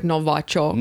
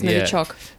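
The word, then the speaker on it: Novichok (Новичок): They don't even like when they say it in English, they don't even pronounce it right. It sounds something like when they Novichok. – No, something like Novachok. – Novachok. – Something weird. It's Novichok.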